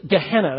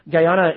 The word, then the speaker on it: Gehenna: And when he talks about the eternal fire, and you see that fiery hell at the end of verse 9, it literally is Gehenna. That's the American pronunciation. Guyana is what they would say in Greek.